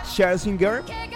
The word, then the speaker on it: Scherzinger: Y el cartel que está confirmado: Robbie Williams, Laura Pausini, Nicole Scherzinger, sí, está bien pronunciado, está el apellido alemán, saludos a todos los alemanes que están por ahí, Scherzinger, Tom Cruise, obviamente, él no canta, pero igual, bancamos a Tom Cruise.